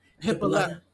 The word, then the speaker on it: Hippolyta: Because I'm like, they literally were back in Chicago, because at the very end, you know, Auntie, okay, is it hippo something? – But Hippolyta. – Yeah, Hippolyta and Lil D. – No, they gotta say it like they did. – Hippolyta.